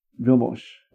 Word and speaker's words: Vilmos: Vilmos. – Vilmos. – Will be how his first name is pronounced.